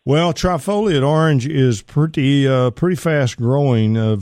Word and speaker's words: trifoliate: One, you've been mentioning trifluora orange. I think that's how you pronounce it. – Trifoliate. – Trifluoriate. That's right. Yeah, foliate. Okay.